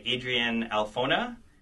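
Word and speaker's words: Alphona: So most of the first two volumes are drawn by Adrian Alfona. Is that, I think, how you say his name? – Yes.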